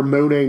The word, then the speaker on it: Money: I can't say it one way or the other, but it's pronounced Monet in the Delta, Northwest Mississippi.